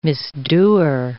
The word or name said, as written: misdoer